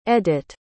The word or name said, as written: edyt